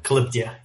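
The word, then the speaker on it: Calyptia: From Calyptea. Is that the correct pronunciation of the company as well? – Yeah, Calyptea. You got both. – Yeah. – Calyptea.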